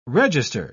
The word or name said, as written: register